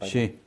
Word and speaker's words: Xi: Xi.